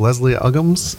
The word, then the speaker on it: Leslie Uggams: But and I think it was Leslie Uggams. Am I saying her name right? – Mm-hmm.